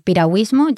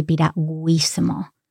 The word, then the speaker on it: piragüismo: And piragüismo, just to know that, the U, there's a diéresis there. – That's right. – And it's just because of pronunciation, because we say piragüismo. – Mm-hmm. Muy bien. Otherwise, if there was no diéresis there, that's the two dots, it would be piragüismo. Mm-hmm. But that sounds very strange, so piragüismo.